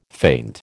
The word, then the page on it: faint: American English